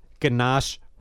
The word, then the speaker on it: ganache: You've got a basic ganache recipe.